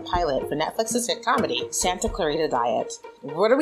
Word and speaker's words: Santa Clarita: The Santa Clarita Diet.